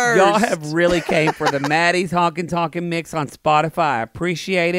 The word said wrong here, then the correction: honky tonkin': I think you're saying it wrong. You've had it as Honky Tonkin' mix. – Yeah. – Instead of honkin' tonkin' mix, which you keep calling it. – Oh, it's honky tonkin'. That's how you say it.